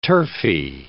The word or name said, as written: turfy